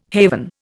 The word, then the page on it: haven: Indian